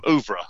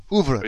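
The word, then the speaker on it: oeuvre: Ouvre, that's what it's called. Ouvre. – So Ouvre. Sorry, I was pronouncing Auvers. Yeah. Ouvre. Ouvre. That's it.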